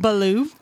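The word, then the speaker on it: Beaulieu: It's B-E-A-U. L-I-E-U. – Right. It's just like a whole, it's like alphabet soup right there. And it's pronounced Baloo like the bear. – Correct. Correct. – From Jungle Book.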